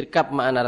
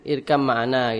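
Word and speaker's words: Irkam ma'ana (ارْكَب مَّعَنَا): Irkam ma'ana. – Irkam ma'ana. – Irkam ma'ana. Ini biasanya kalau belum belajar tajwid, biasanya irkab makna rata-rata bacanya. Ini baknya hilang, kamil. Kamil, langsung baknya hilang, langsung masuk mim.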